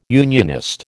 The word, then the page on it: unionist: American English